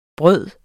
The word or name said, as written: bryder